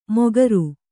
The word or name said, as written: ಮೊಗರು